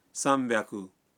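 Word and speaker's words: سام بیاکو (三百): بعض آداد کا تلفظ کچھ مختلف ہے مثلاً 300 ہے سام بیاکو 600 ہے روپ بیاکو اور 800 کو کہتے ہیں ہاپ بیاکو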